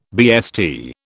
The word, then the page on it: BST: British English